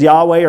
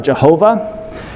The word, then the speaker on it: Yahweh: Many pronounce Yahweh as Yahweh or Jehovah. We no longer know for certain the exact pronunciation.